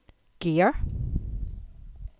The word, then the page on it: Gear: English